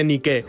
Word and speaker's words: Nike: Y en la de fuera, la visible, pues una presentación muy, muy, muy parecida a la Nike americana, hasta en la pronunciación, porque pusieron Nike a la inglesa en vez de Nike.